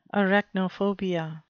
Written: arachnophobia

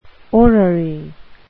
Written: orrery

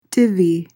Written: divvy

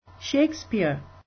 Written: shakespeare

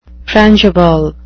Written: frangible

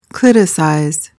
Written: cliticize

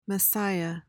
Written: messiah

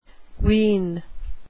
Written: ween

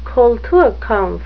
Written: kulturkampf

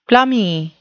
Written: plummy

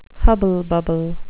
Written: hubble-bubble

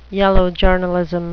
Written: yellow journalism

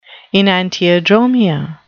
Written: enantiodromia

